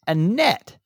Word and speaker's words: Annette: Um, she said it's pronounced Annette.